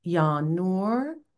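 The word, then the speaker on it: Ya Nur: It is Yanur, which is N-U-R, but pronounced N-O-O-R. Ya'alim, spelled A-L-I-M, but pronounced A-L-E-E-M, and Yahai.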